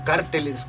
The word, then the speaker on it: cárteles: Es que esos carteles o cárteles, ¿cómo se dice? – Cárteles.